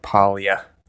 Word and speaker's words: Paglia: First book I finished this week is called Glittering Images, and it's by Camille Paglia. I hope I'm saying her name right. It's got a G in there, but I think it's silent.